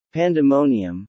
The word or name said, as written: Pandemonium